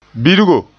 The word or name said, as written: birgo